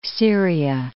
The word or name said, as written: Syria